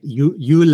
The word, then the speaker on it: Jule: Jule is how you say it in Norway. Is that right? – Yeah, that's correct.